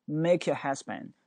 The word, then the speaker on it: make your head spin: Make your head spin，而且你会发现那个 head 那个 d 是没有发音的，因为后面加一个 spin 那个 s 嘛。